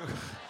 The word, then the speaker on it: Bathsheba: Bathsheba.